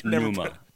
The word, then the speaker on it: Numa: Like pneumonia? Like, yeah, Numa. – Numa. Okay. – It's a lyric in the song. Numa.